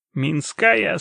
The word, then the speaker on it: Минская: «Минская, 115!» — благодушно напомнил Ащ. Сказал он по-южному с ударением на «а».